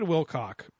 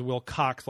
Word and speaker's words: Wilcock: But anyway, So David Wilcock is on here, and I've been miss pronouncing his name as Wilcox, like with an X.